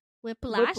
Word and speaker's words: whiplash: Whip slap. Is that how you say it? Whip slap? – Whiplash. Whiplash. Whiplash.